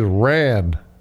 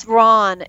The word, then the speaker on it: Ran: Next movie is Ran. R-A-N. – He ran all night? – No, it's Ron. It's pronounced Ron. It was a Japanese film.